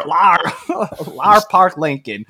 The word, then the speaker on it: Lar Park Lincoln: I don't know if that's a flashback, if she's seeing his spirit, But Lar Parker, Lincoln, Lara? I hope I'm saying this right. She's reprising. Oh God, he doesn't know another name. He's messing up another name. – Lar Park Lincoln.